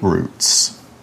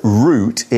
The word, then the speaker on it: route: and there are also escape routes. So the word route is often pronounced as route. It's maybe more common in American English. Route is a bit more common in British English. Both pronunciations are okay.